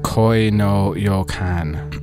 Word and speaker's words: Koi no Yokan: From the album Koi no Yokan.